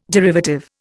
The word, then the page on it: derivative: Indian